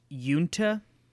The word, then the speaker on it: Uinta: So I'm going to say it's pronounced Yunta. It is spelled U-I-N-T-A. So if I'm saying that wrong, I apologize.